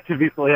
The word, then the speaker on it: Caviezel: Jim Caviezel. – Yeah, Caviezel. Yeah, I pronounced it wrong.